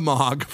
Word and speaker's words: Mogg: Is Mogg, is that how you say his name? – That's how I've said it.